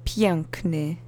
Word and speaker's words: piękny: Next one is piękny, which is an adjective that means beautiful. It has the N letter in the alphabet, which we've seen before. And the accent is on the first syllable.